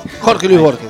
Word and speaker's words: Jorge Luis Borges: Hay mucha gente dentro de lo que es el auditorio Jorge Luis Borges, y ahí lo dije bien, ¿no? Jorge Luis Borges.